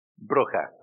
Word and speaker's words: Brugge: Brugge, okay. I've been to Brugge, okay.